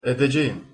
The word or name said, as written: edecegim